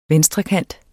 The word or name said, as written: venstrekant